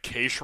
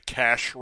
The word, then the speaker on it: cache: Before anyone starts to question what Case Raiders are, it's actually Cash Raiders, C-A-C-H-E.